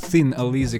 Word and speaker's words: Thin Lizzy: Группа, о которой мы сегодня говорим, называется Thin Lizzy, маленькая ящерица, Thin Lizzy, как ее правильно говорит по-английски,